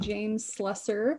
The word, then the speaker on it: Slusser: We're going to have a conversation today with Ashley Bradley and James Slusser.